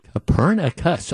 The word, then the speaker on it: Copernicus: Copernicus. Okay, and four syllables. All right. – Copernicus, such as Nicholas Copernicus.